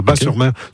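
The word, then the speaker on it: Batz-sur-Mer: Oui, Bat, on prononce Bas, le T ne se prononce pas, Bas-sur-Mer.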